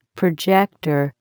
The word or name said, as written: projector